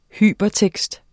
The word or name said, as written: Hypertekst